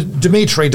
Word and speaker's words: Dimitri: Just go to Dimitri. Dimitri's with three I's. D-I-M-I-T-R-I.